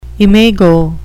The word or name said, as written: imago